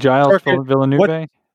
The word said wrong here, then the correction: Gilles Villeneuve: Gilles Villeneuve.